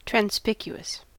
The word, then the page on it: transpicuous: American English